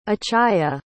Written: achaia

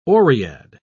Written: oread

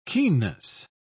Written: keenness

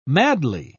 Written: madly

